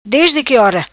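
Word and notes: Ora Desde Qui: patua

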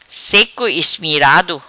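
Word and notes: Seco Ismirado: Patuá

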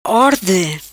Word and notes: Orde: patua

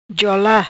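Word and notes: Ja Ola: Patuá

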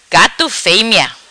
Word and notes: Gato Femea: patua

